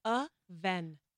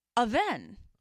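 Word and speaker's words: Avène: It's pronounced Avene. – Avene.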